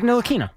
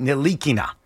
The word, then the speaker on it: Ntilikina: Frank Ntilikina.